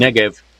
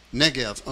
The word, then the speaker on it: Negev: I'm sorry, pronounce that for again. – Negev. – Negev, okay. The propaganda war.